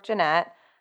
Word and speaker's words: Jeanette: My name is Brooke Jeanette. – How did I say it? Janet? – You said it right, but you I said just, it like, right.